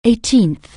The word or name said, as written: eighteenth